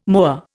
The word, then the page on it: moa: Indian